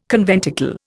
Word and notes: conventicle: Indian

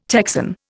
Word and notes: Texan: Indian

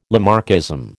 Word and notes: Lamarckism: American English

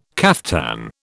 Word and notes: caftan: British English

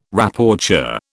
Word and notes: Rapporteur: British English

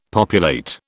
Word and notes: populate: British English

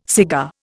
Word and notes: cigar: Indian

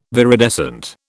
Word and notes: viridescent: British English

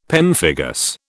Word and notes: pemphigus: British English

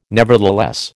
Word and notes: nevertheless: American English

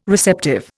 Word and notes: receptive: Indian

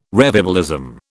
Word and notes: revivalism: British English

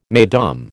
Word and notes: Mesdames: American English

